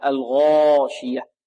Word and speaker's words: الغاشية: الغاي هنا مفخمة لأنها من حروف التفخيم، وحروف التفخيم كما قلنا سبعة: خص، ضغط، ماذا؟ قض. طيب، انطق معي: خا، صا، ضا، غا، طا، قا، ظا، ظا، ظا. والألف هذه بارك الله فيكم مفخمة لأنها تتبع ما قبلها، الغاشية، لا نقول الغاشية، البعض يقول الغاشية.